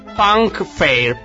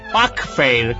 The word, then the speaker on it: Puck Fair: Dai, nella contea di Kerry per il Punk Fair. Puck Fair, credo si pronunci così.